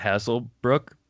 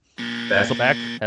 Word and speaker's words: Hasselbeck: Matt Hasselbrook. – Hasselbeck.